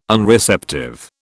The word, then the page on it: Unreceptive: British English